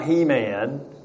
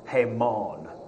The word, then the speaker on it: Haman: No, this is not He-Man. It would actually be pronounced Haman in the Hebrew, and I'm probably slaughtering that anyway.